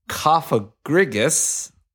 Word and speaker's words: Cofagrigus: Cofagrigus. Which is a better pronunciation than the one that Gilbert used two episodes ago.